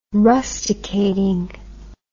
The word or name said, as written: rusticating